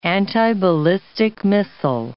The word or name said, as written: antiballistic missile